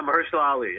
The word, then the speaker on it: Mahershala Ali: Mahershala Ali? – Yeah, Mahershala Ali, yep.